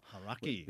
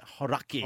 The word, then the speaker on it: Hauraki: Look in the notes here. It's got the pronunciation laid out. – Yeah, Hauraki. – Hauraki.